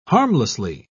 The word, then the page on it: harmlessly: British English